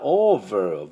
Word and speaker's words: over: それから、 over、 v なんで、ここは要注意ですね。唇、前の歯に唇を当てて、 v、 v、 f の音、 f が、 v に変化します。